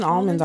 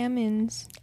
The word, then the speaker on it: almonds: I like almonds, but even almonds aren't that great. – That's another word that people pronounce weirdly. They don't pronounce the L on there either. They just say almonds.